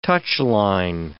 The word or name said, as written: touchline